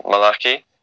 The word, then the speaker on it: Malachi: The Hebrew term is more sounds like more Malachi. – That's the correct way to say it, or the Hebrew right. way to say it anyway.